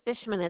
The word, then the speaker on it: Bishman: My last name is Bishman. It's spelled B like butter, I-S-H-M-A-N.